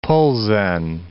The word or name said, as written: Plzen